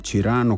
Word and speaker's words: Cyrano: Cyrano, così all'italiana in questo caso, di nuovo, perché Francesco Guccini alla fine la pronuncia così, è stata scritta, dicevo, con Beppe Dati, ed è una canzone d'amore.